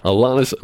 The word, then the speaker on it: Alanis: Atlanta Morissette. – Alanis. – I knew I was going to say that wrong. Alanis Morissette.